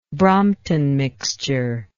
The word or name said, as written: Brompton mixture